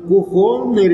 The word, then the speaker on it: кухонный: Я говорю, кухонный революционер, с ударением на «о» в слове «кухонный», никому не обращаясь, пробубнил Черников.